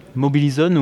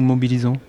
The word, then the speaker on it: Mobilizon: Mobilizon ou Mobilisant, Les deux Comme vous voulez on prononce ça comme on veut.